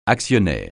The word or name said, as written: actionnaire